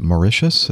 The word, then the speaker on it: Mauritius: Was it Mauritius? Is that the way you pronounce that? – That would be correct.